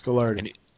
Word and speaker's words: Gagliardi: And Coach John, how do you pronounce his last name? – Gagliardi. – John Gagliardi. – I know some people say Gagliardi, but I thought it was Gagliardi. – It's Gagliardi.